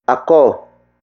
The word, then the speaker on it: ako: Word for word, it's pumasyal ako sa inyo kahapon.